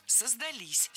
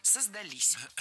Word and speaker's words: создались: И правильный вариант – «создались». Здесь уже прослеживается другая тенденция. Возвратная часть «с» притягивает к себе ударение. И чаще всего ударение ставим на окончание – «создались».